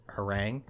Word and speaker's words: harangue: Yeah, but I'm also 90% sure it's pronounced harangue.